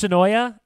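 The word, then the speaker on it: Senoia: I'm told it's pronounced Sonoy, but I refuse to do it. It's Sonoya. I have to keep saying it now. I can't bring myself to say Sonoy. Someone email me or tweet at me if I have that wrong. But I'm pretty sure it's Sonoy is how the locals pronounce it.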